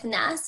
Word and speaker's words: Ness: Yeah, so my Instagram handle is Healing with Ness. It's Healing W period Ness, and Ness is spelled N-E-S-S.